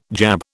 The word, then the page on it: jab: British English